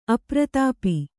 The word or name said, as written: ಅಪ್ರತಾಪ